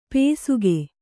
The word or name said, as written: ಪೇಸುಗೆ